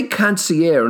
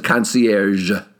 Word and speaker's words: concierge: And if you say concierge in an attempt to be French or an attempt to be high and mighty, to be suave, I've got news for you. The French pronounce it concierge.